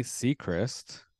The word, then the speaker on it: Sechrist: we are chatting with Courtney Sechrist.